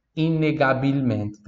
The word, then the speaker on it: inegabilmente: Inegabilmente.